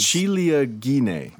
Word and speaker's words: ciliegine: So 1 cup of mozzarella, I can never pronounce it. – It's ciliegine. – Oh, you did that really well. You did that. I'll do it a little more with a little ciliegine.